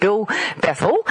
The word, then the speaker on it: Berthel: I'm here with Bill Berthel. Did I pronounce it right? I got that okay? – Yeah, that's great. Berthel.